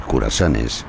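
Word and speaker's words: cruasanes: Y lo pronuncio así, curasanes, para fastidiar a los franceses que me estén escuchando. Curasanes, así es como se dice aquí en España.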